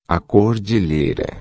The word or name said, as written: cordilheira